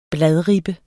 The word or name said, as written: bladribbe